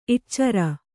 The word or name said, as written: ಇಟ್ಚರ